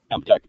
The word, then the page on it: abduct: American English